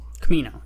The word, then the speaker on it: Kamino: And then he goes to Kimono. Am I saying that right? Kamino, yeah.